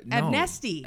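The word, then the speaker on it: Abnesti: So Dr. Stephen Absenti, am I saying it right? – No. – Abnesty. Abnesty. It's just such a stupid name.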